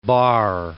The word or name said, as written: Bart